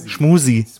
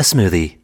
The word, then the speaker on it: Smoothie: Ich finde, ihr solltet erstmal einfach Smoothie richtig aussprechen. Das wird mich schon freuen. – Schmoosie. – This is a smoothie. It is a smoothie. Now repeat after me.